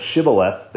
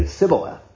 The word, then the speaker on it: Shibboleth: You know, it's like that apparently there was something going on with these men of Ephraim that they could not pronounce that SH sound. So instead of Shibboleth, they would say Sibboleth.